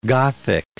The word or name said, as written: Gothic